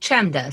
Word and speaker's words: Chemda: My name is spelled Chemda, C-H-E-M-D-A, but you can look for the podcast Keith and the Girl.